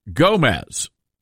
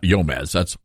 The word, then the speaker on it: Gomez: And no, the G is not silent, Dell. It's not Yomez. But it is silent on like Guatemala and guacamole. The G is not silent here.